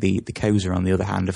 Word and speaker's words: Xhosa: The largest, if I recall, are the Zulu and the Xhosa, which is spelled X-H-O-S-A. Now, don't worry about this, folks. I had trouble pronouncing this, and I thought, how do you pronounce the X? But that's what they're called, the Xhosa.